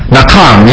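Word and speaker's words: nacham: The first is a Hebrew word, nakam. Nakam, N-A-H, strong H, A-M, or some will spell it C-H, but it's nakam, either way, it's nakam.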